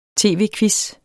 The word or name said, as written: tv-quiz